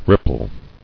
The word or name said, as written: Rippling